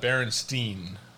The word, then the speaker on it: Berenstain: But it's Berenstain. Berenstain. I don't even know if I'm saying that right. – Berenstain, yeah. – Yeah, Berenstain. I always thought the word bear was in their name, and it was Steen.